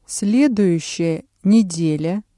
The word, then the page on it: следующая неделя: Russian